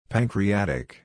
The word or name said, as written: pancreatic